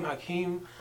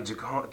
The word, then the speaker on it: Joaquin: That's how you pronounce his name, Joaquin. I thought it was like Jaquan.